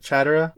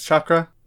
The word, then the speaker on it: chakra: Naruto mispronounces chakra. He calls it chatara, which Konohamaru imagines a funny ninja kitty cat and asks if he means chakra.